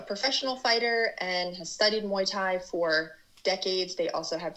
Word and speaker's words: Muay Thai: And I have to ask, what is, and I might be saying it wrong, is it Muay Thai? – Yeah, Muay Thai.